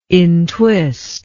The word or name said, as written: intwist